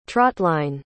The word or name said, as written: trotline